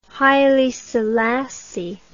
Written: Haile Selassie

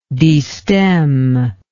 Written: destem

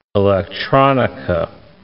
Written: electronica

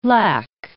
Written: lac

